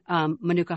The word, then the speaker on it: Manuka: And then Manuka honey.